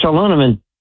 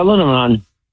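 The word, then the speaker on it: aluminium: There's an extra syllable in there. – Oh. – Yeah, aluminum.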